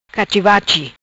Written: Cachi-bachi

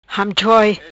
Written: Hamchoi

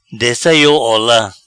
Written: Dessa iou ola

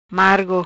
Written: Margo

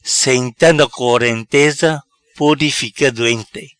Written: Senta na corenteza

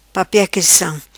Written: Papia cristam

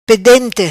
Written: Pedente

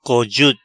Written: Cojut

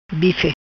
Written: Bife

